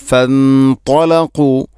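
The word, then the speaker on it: ഫൻതലഖു (فانطلقوا): ഫൻത ഇഖ്ഫാം ബിഗുന, തലഖു ത്വാഇൻ്റെ ഉച്ചാരണം, അതുപോലെ ഖാഫ് തലഖു. ഫൻതലഖു, ഫൻതലഖു വഹും ഇഖ്ഫാം ബിഗുന്ന, അതുപോലെ ത്വാ ഖാഫ് ഈ രണ്ടക്ഷരം. ഫൻതലഖു അല്ല, ഫൻതലഖു അല്ല, ഫൻതലഖു.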